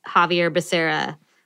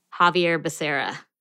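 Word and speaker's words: Xavier Becerra: His name is Javier Becerra. So I got both his first and last name wrong. Javier Becerra.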